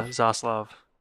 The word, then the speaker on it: Zaslav: Isn't David Zaslav, is that how you say it? Zaslav? – Yeah, Zaslav.